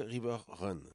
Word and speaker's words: River Run: Ah, que je prononçais, allez savoir pourquoi River Run ou River Round, et que mon mot d'un niveau d'anglais m'oblige à prononcer River Run.